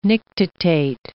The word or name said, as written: nictitate